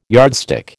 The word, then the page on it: yardstick: American English